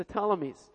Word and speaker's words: Ptolemies: That's actually spelled with a P, the Ptolemies.